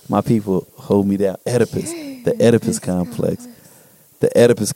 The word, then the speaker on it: Oedipus: How do you say it? – Oedipus. – I said Oedipus. Oedipus, thank you.